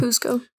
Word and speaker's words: Kuzco: Did I say it right? Kuzco? – Yeah, Kuzco.